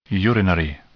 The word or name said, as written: urinary